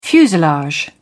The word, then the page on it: fuselage: British English